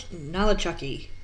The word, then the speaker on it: Nolichucky: A man was arrested at, after he floated down the Nolichucky, probably pronouncing that wrong, Nolichucky River Sounds while right.